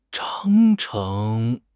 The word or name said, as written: 章程